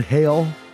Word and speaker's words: Hale: Isn't it pronounced Nathan Hale as well? You said Nathan Hall. Like Anthony Michael Hall.